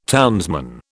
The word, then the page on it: townsman: British English